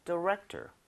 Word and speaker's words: director: 자, I majored in math, but now I'm a movie director. – Movie director. 어? – T-O-R. – 디렉토리구나, 디렉토리. – 근데 발음은 디렉터 맞는데 너무 토르는 아니고요.